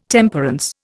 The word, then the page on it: Temperance: Indian